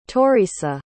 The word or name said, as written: toireasa